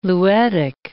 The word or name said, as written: luetic